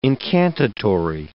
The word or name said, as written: incantatory